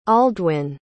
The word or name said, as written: aldwin